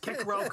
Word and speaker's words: Cicero: But I thought it was Cicero. – It would be pronounced Cicero correctly in Latin.